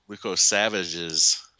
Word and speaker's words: Lukosevicius: I always say that wrong. Lukosevicius. – Luco Savages. – Luko Savages. – That's it. – There's no G in there.